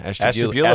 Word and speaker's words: Ashtabula: Ashtabula, Ohio? – Yeah, Ashtabula.